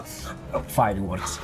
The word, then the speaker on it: Fireworks: A un certo punto è successo quello, numeri, cioè la fuochi d'artificio, proprio papa, fireworks, un certo... – Fire. The southern English accent is non-rhotic, meaning the R's are not often pronounced. Fireworks. Fire.